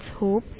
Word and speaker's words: Hope: It's Hope.